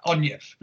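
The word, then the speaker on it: Anie: And she, if you have a look on my friends list on Instagram, but it's the Irish, well, Scottish, her dad was, so it's A-N-I-E or A-I, whatever, Anya, but yeah, Anya, so it's like the way, it's not spelt the way it's pronounced, so it's A-N-I-E,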